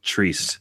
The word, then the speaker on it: Trist: Oh, and just FYI, public service announcement, he mentions his name rhymes with priest, so it's Matt Trist.